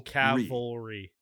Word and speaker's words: cavalry: Okay, so just say it. Cavalry. That's how you say it, yes.